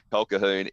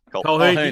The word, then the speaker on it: Colquhoun: Colquhoun. You get it wrong. You get it wrong every year, and I was waiting for you to say it wrong again. It's Irish. Colquhoun. Not Colquhoun.